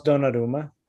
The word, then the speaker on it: Donnarumma: You were pretty close, Donnarumma.